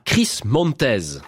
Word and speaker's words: Chris Montez: The More I See You, la version de 1966, chantée par Chris Montez. Ça se prononce comme ça.